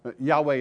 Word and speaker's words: Yahweh: Yahweh is a better pronunciation according to the Hebrew than Jehovah. That's mixing two words. Okay, Yahweh is probably the way it was pronounced.